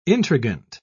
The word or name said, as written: intrigant